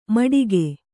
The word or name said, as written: ಮಟಿಕೆ